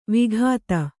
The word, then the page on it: ವಕ್ತø: vaktř